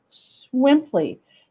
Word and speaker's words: Swimply: Okay, I'm going to spell it because it sounds weird. Swimply, S-W-I-M, which means swim, P-L-Y, Swimply.